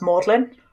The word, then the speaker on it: Magdalen: Oxford is really weird, and it's spelled Magdalen, but it's pronounced Magdalen.